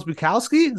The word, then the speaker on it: Bukowski: Um, it was a Bu- Charles Bukowski.